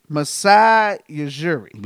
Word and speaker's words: Masai Ujiri: Raptors president Yajuri. I want to say, is that how you say it? – Dude, don't give me a line, nigga. That sounds like a disease. I'm about to bless you. Like, man, every time you say his name, I'm like, bless you. – His name is Masai Ujiri.